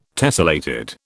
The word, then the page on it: tessellated: British English